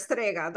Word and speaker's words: Strega: But I wanted to start with the fact that you identify as a Strega, and I've probably pronounced that wrong with my super Aussie Bogan accent. – People have done way worse. Strega, that's all it is. Yeah, that's perfect.